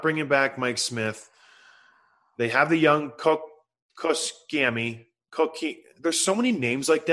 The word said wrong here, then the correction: Koskinen: So it's Koskinen. Koskinen. He's not young, though.